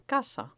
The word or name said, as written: casa